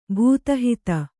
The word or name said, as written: ಭೂತಹಿತ